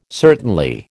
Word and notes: certainly: American English

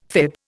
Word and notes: fib: Indian

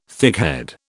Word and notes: thickhead: British English

